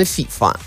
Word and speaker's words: FIFA: Ну, вообще-то на английском звучит именно FIFA. – Да? – Да, они ударение, по-моему, на первый, да, ставят. Слог.